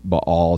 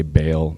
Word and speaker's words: Baal: And then later in verse 16, we see that they were worshiping Baal too. I know we all say Baal, but it's Baal. Like that's how you say it.